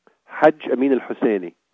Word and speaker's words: Hajj Amin al-Husseini: Hajj Amin al-Husseini. – All right, that's a little rough for our Western ears to process. – Hajj Amin al-Husseini.